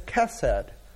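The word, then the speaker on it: chesed (חסד): The biblical word that is used in the Old Testament to describe this is chesed. We translate that many different ways, but most often as loving kindness. It's spelled appropriately with an H, but you pronounce it like you would Christmas with a CH.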